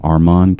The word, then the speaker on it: Armond: Armand Kennard. First name, A-R-M-O-N-D. The D is silent. And Kennard, K-I-N-A-R-D.